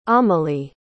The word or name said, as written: amélie